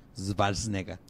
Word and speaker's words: Schwarzenegger: Porque también se supone que Schwarzenegger es Schwarzenegger. – O sea, la W y la, eh, la... Se pronuncia como V.